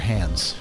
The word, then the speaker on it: Hans: You say Hans or Hans? – Hans.